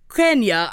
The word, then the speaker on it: Quenya: She's singing in Quenya. I think that's how you pronounce it. – Quenya? – Q-U-E-N-Y-A, the constructed language based on Greek, Latin, and Finnish.